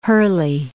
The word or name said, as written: Hurley